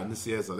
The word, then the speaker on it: Nicieza: That's high, Nicieza, that's right. – Yeah, that was, I think that's how you pronounce it.